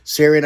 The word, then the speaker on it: Tsarion: This is by Michael Sarion, as well as Nicholas Hager, and that's H-A-G-G-E-R. Sarion, I said, is spelled T-S-A-R-I-O-N. The T is silent.